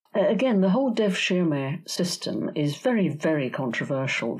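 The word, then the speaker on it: devşirme: Going along with that pragmatic approach, you also explain a bit about the, I'm not sure if I'm pronouncing this right, the Dervishma system. Dervishma system, yes. And you write that the type of meritocracy that was incorporated into that in that system is hard to achieve even today.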